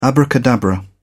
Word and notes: abracadabra: British English

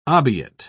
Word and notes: obiit: British English

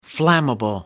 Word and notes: flammable: American English

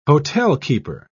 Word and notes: hotelkeeper: British English

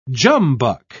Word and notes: jumbuck: British English

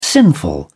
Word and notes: sinful: British English

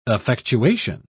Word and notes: effectuation: British English

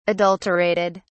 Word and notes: adulterated: British English

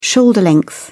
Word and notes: shoulder length: British English

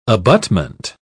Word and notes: abutment: British English

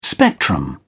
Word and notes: spectrum: British English

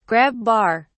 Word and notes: grab bar: British English

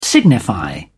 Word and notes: signify: British English

